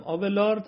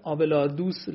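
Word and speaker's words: Abélard: بله بله، اون دالم که فرمودین عرض کردم، آبلارد تلفظ فرانسهشه، آبلارد تلفظ انگلیسیش یا آلمانیش میشه، آبلاردوس لاتینیش میشه، پتروس آبلاردوس اگر بخوایم لاتینی بگیم.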